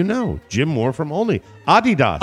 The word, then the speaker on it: Adidas: Regarding Adidas, your mother was actually pronouncing the name correctly.